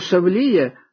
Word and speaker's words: шавлія: Наголошую, що слово «шавлія» в українській літературній мові має наголос не на першому, а на другому складі.